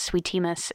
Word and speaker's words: Sweetie Miss: I think what I love about Sweetie Miss is, sorry, let me say it right. Sweetie Miss. The emphasis matters.